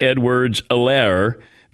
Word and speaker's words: Edwards-Helaire: and Clyde Edwards-Helaire. The H is silent.